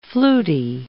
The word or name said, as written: flutey